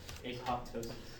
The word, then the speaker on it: apoptosis: No, that P is silent. – Apoptosis.